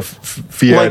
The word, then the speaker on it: Fieri: We got Guy Fieri.